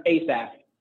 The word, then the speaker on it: Asaph: Yeah, so down here you have, it's Asaph, that's how you pronounce it? Not Asaph? – It's Asaph.